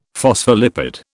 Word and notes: phospholipid: British English

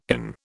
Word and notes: inn: British English

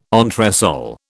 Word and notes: entresol: British English

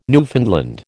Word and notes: Newfoundland: American English

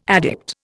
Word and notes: addict: Indian

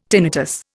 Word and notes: tinnitus: Indian

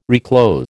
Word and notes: reclothe: American English

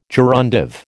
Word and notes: gerundive: American English

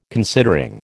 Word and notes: considering: American English

